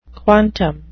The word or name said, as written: quantum